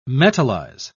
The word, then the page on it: metalize: British English